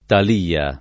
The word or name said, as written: Talia